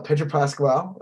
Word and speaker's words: Pedro Pascal: And then somebody posted a picture of, like, Pedro Pascal.